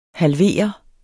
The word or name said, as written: halvere